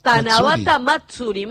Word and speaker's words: Tanabata Matsuri: Tanabata Matsuri.